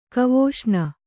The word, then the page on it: ಗವೇಷಣೆ: gavēṣaṇe